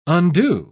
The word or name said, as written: undo